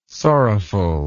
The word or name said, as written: sorrowful